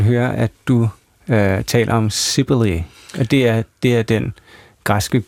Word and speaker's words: Cybele: Cybele, udtaler du det sådan på... – Ja, altså faktisk siger jeg Cybele, fordi jeg har lavet en blanding af to mytologiske figurer. Den ene, det er Cybele, en græsk gudemoder, og den anden, det er Cybele, som er et orakel.